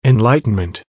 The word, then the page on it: enlightenment: American English